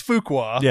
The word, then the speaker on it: Fuqua: How did you guys pronounce that? – I said Antoine Fuqua. – It's Fuqua.